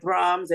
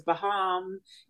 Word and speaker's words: Brahms: I was still, you know, mispronouncing things like Brahms as Baham, you know.